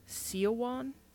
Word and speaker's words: Siobhan: the name Siobhan, which I already know gives a lot of people a lot of trouble. S-I-O-B-H-A-N, Siobhan. It's also pronounced Siowan in some parts of Ireland, so I understand why the accents are all different when you're pronouncing the same word completely different.